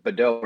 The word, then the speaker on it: Beddoe: It's Beddoe, but Beddoe works too.